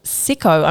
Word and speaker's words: Sycco: Okay, this week I have been listening to a song called Dribble by someone called Sicko. I'm pretty sure that's how you pronounce it. It's spelt S-Y-C-C-O. Not 100% sure on the pronunciation.